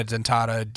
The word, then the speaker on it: Dentata: And it's just Dana Dentata, D-A-N-A, yep. – D-E-N-T-A-T-A. – Yeah. – There you go. It's spelled how it sounds.